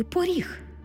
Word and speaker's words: поріг: Ми-то знаємо, що це слово пишеться з буквою «о». Але якщо будемо писати транскрипцію, ми побачимо, що «о» наближається до «у». «Поріг». Почуйте, як я вимовила це слово. Звук «о» наближається до звуку «у», що ми позначимо в транскрипції.